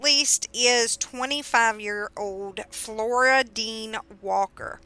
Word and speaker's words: Floradean: And for some reason, I keep wanting to say her name as Floridian. Floridian. I guess just that's what my eye sees. so if I mess it up, that's why. But it's Flora Dean.